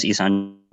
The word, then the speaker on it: Yxandro: My name's Yxandro.